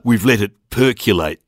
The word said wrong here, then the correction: percolate: The word you are looking for is percolate, not percolate. – What do you mean? – I mean you didn't pronounce that word properly, and it would be a shame if no one picked you up on it. – It is percolate, isn't it? – No, there is no U in that word. It's spelt with an O, percolate.